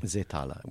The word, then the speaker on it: Seethaler: I really think that Seethaler, if I'm saying that correctly. – Seethaler would be the German.